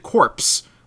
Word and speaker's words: CORPS: like C-O-R-P-S, but they pronounce it corpse, like as in the corpse. But of course, C-O-R-P-S, we've been beating into our heads our entire lives that is pronounced core, as an army corps.